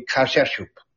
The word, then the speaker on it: Kaapschoop: and I have to spell it because it's South African, K-A-A-P-S-C-H-O-O-P, that would be Kashashoop, South Africa, was spotted by Johann Hein, and his name is J-O-H-N-H-E-I-N-E, from his airplane.